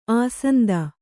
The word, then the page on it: ಅಶಾಂತ: Kannada